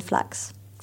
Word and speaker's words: Flachs: Um, it's my first name and last name, so Amandine Flachs.